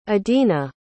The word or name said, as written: adinah